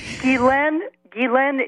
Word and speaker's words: Ghislaine: No, Ghislaine is a proper French name. I'm a former French Canadian, so Ghislaine is a correct name.